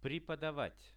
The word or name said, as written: prepodavat